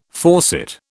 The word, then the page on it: faucet: British English